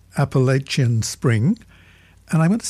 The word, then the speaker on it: Appalachian: But I have an old, old Irish friend who moved to the foothills of the Appalachians some 30 years ago, and we still keep in touch. And he tells me it's definitely pronounced Appalachian. So unless you know better, you stick with that one.